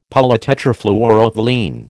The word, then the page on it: polytetrafluoroethylene: American English